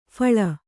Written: ಫಲಿ

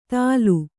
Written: ದಾಲು

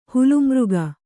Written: ಹುಲುಮೃಗ

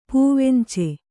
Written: ಪವುಂಚಿ

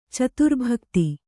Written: ಚತುರ್ಭಕ್ತಿ

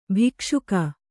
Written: ಭಕ್ಷಕಿ

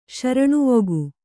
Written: ಶರಣುವೊಗು

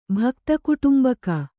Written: ಭಕ್ತಕುಟುಂಬಕ

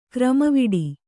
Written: ಕ್ರಮವಿಡಿ

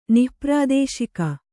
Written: ನಿಃಪ್ರಾದೇಶಿಕ

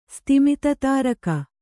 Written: ಸ್ತಿಮಿತತಾರಕ

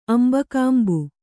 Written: ಅಂಬಕಾಂಬು

